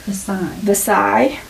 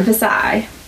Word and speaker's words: Versailles: Versailles. – I was doing so well with my pronunciation, Versailles.